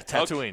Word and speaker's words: Tatooine: Our first call from Tatooine. – I love it. – Did I say that right? – Yeah, Tatooine.